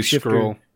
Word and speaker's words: Skrull: director alongside Ben Mendelsohn, Skull, however you pronounce it. Supposed to be Skrull. You're missing an R.